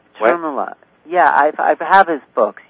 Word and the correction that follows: Thirumular: No, his name is pronounced Thirumular.